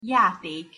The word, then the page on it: jatek: Hungarian